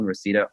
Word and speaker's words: Reseda: Yeah, all my socials are my name, Richie Reseda. Richie, like you think it's spelled, Reseda, R-E-S-E-D-A, like the neighborhood in LA.